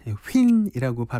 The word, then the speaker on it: HYNN: H-Y-N-N, 휜이라고 발음하더라고요.